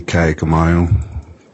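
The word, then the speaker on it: Kaaiakamanu: Vincent Keone Kaikamanu. Last is K-A-A.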